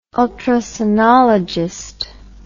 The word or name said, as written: ultrasonologist